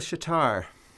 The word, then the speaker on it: shuitar: It's called a shitar. – Emphasis on the second syllable. – Yes. – Tar, yeah. Right? – Spelling S-H-U-I-T-A-R, just like mm-hmm. guitar.